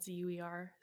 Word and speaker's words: Zuercher: Last name is spelled Zuercher, Carley with an E.